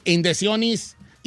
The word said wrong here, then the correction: inyecciones: Inyecciones. – ¿Eh? – Inyecciones. – ¿Yo qué dije? – Indiciones.